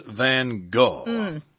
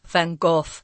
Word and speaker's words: van Gogh: But the one who's the most popular from Holland is probably Vincent van Gogh. Or Gough. – Yeah, if you're in his own native country, you would actually say Van Gogh. But Americans say Van Gogh, and we're going with that, guys.